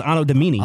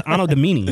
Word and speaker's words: Anno Domini: No, the actual word is Anno Domini.